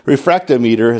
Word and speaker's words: refractometer: It is refractometer. It sounds like a made-up word, but that's what it's called.